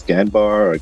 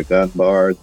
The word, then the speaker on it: Ganbar: It's Ganbar or Ganbar, depending on your pronunciation.